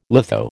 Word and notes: litho: American English

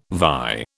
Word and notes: vie: British English

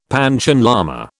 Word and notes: Panchen lama: British English

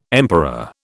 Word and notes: emperor: British English

